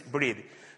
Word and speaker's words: breathed: My wife say, don't pronounce ED, breathe. So, okay, the scripture is breathed.